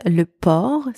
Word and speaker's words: port: The first word, le port, spelled P-O-R-T, means the port, as in the harbor, or also can mean the posture. The letter O in that word is pronounced O, not O, O. And the final consonant T here is silent. In the second word, port, spelled P-O-R-C, means pig or pork.